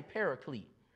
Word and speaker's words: Paraclete: Somebody say Paraclete. If you're trying to figure out how to spell it, it's P-A-R-A-C-L-E-T-E. That's Paraclete. Once again, that's P-A-R-A-C-L-E-T-E, Paraclete.